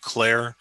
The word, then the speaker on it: Klehr: My name is Nick Clare. Last name is spelled K-L-E-H-R on behalf of Appellant Martinez.